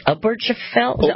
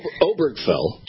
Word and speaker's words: Obergefell: How do you say that word? – Oh, it's Obergefell. – Obergefell. – Thank you.